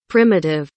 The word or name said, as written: primitive